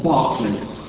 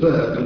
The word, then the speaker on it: Berkeley: He studied at what you quaintly call the University College Berkeley, which we in England would call the University College Berkeley. How we in England get to pronounce B-E-R-K, bark, when it's quite clear it should be B-A-R-K, and you pronounce it Burke, and you think you're odd.